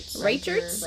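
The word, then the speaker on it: Riecherts: It's R-I-E-C-H-E-R-T-S. So sorry if I butchered that, guys. – Reichert's.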